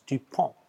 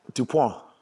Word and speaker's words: Dupont: It's a silent T. It's Dupont. – Dupont.